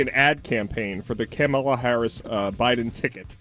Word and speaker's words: Kamala: Can we get a pronunciation on her name, please? – Kamala. – I thought it was Kamala. – Loser. – Kamala.